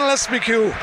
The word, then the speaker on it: Lespiaucq: It's Quentin Lespicue. It's spelled L-E-S-P-I-A-U-C-Q.